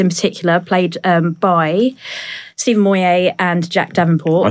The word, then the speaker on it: Moyer: It's an R rather than a T. Moyer.